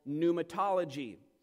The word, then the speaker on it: pneumatology: So in order to more effectively understand this, theologians have created a very special category of study about the Holy Spirit called pneumatology. And that is a word that is spelled with a silent P.